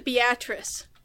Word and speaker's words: Beatrice: So in Nebraska, if you search for the name, we have a town whose name is spelled exactly as it is spelled on Morg's cake, but we don't pronounce it that way. We pronounce it Beatrice.